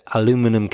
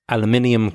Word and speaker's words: aluminium: He said aluminum cans, which is pronounced aluminium cans in the UK, which is quite different really.